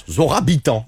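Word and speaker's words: Zohra Bitan: En fait, ça se prononce Zora Bitan.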